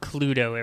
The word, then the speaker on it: Cluedo: It's called Cluedo everywhere else. – Yes. – To rhyme with Bluto? So people have a positive association with Popeye?